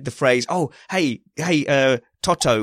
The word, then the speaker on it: Toto: Oh, well, Kyle decided it was Tato. Called Toto, yeah. – Toto.